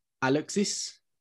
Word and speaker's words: Alexis: Summer of 85 is based in Normandy, and it's about a boy called, and pardon my pronunciation, Alexis, because it's the French pronunciation of the name,